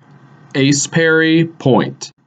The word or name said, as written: Ace Perry Point